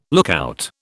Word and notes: lookout: British English

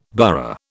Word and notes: burgh: British English

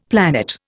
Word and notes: planet: Indian

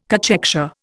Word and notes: cachexia: Indian

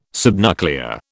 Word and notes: subnuclear: British English

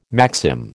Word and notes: maxim: American English

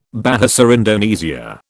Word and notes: Bahasa Indonesia: British English

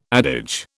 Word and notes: adage: British English

